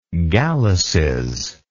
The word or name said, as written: galluses